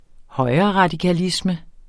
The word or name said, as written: højreradikalisme